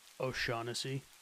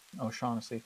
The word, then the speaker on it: O'Shaughnessy: And y'all know this as the O'Shaughnessy Dam. Excuse me if I mispronounced that. – O'Shaughnessy. – O'Shaughnessy, thank you.